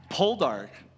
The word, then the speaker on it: Poldark: Podark. I didn't say it right, did I? What's it called? Poldark.